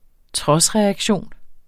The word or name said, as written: trodsreaktion